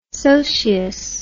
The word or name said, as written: socius